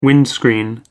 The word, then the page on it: windscreen: American English